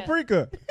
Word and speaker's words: paprika: Paprika. – Oh yeah, he does say it like that. – No way. How are you supposed to say it? – It's paprika.